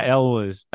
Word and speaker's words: Elwes: I can never pronounce his last name. Cary Ills, who El- is also Elwes? yeah Elwes Elwes. That's it.